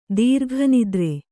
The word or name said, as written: ದೀರ್ಘನಿದ್ರೆ